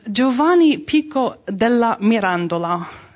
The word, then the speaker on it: Giovanni Pico della Mirandola: It's an even great, portrait here is an anonymous portrait of this character, whose name is Giovanni Pico della Mirandola, is the proper Italian pronunciation of his name. Things with R's tend to be a bit difficult in Italian, so Mirandola will work.